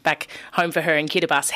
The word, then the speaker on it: Kiribati: Kiribati is probably how you'd read it if you saw it, but it's pronounced Kiribati in Micronesia.